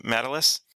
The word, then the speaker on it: Matalas: How do you say his name? Matalas. Thanks, Terry Matalas.